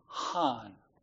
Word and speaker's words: chen: The first one we'll look at is transliterated, spelled in English, C-H-E-N. Okay? And it's pronounced han. I can't give the han.